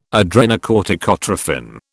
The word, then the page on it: adrenocorticotrophin: British English